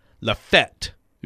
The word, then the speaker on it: Lafayette: People from Lafayette, Tennessee, which is what I just said, pronounce it Lafette.